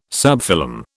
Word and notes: subphylum: British English

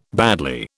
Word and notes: badly: British English

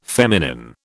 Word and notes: feminine: British English

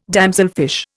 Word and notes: damselfish: Indian

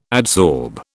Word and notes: adsorb: British English

